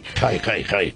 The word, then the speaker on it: Hay: Quiero saber también cómo se dice, si se dice Jai o Ai. – Jai, queda muy bien, esto es gitano además. Jai, Ai, Jai. Yo toda la vida lo he dicho Jai en radio, vamos. – Vale, es que se escribe H-A-Y.